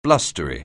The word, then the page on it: blustery: anglais